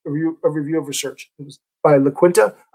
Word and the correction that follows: Iaquinta: Oh, you know what? That's an I. Iaquinta.